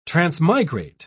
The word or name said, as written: transmigrate